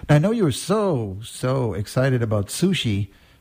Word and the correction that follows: Tzu Chi: Did I say that right? But maybe Su-chi, you could repeat that and tell us. – Su-chi. So it's spelled T-Z-U-C-H-I.